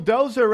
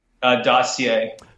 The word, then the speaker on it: dossier: And basically the Steele Dozer right now, Dossier Dozer, what is it? Dossier or Dozer? I've been saying it wrong forever. – Dossier.